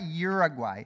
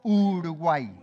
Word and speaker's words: Uruguay: No, not Uruguay. Uruguay. Very good.